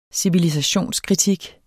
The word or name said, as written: civilisationskritik